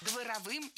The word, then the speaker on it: дворовым: Дворовым языком – это неверно, правильно, дворовым. В прилагательном, образованном от существительного «двор», ударение ставим на второй слог – дворовый, дворовая, дворовыми.